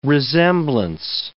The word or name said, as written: resemblance